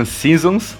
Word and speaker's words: SZNZ: Esse projeto se chama SZNZ, que se pronuncia Seasons, e a gente vai ter um álbum para cada estação do ano, fazendo uma referência às quatro estações à obra do Vivaldi.